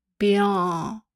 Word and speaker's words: Béarn: Béarn heißt es, aber es ist natürlich falsch ausgesprochen, also B-E-A-R-N mit der Betonung auf dem E. Béarn.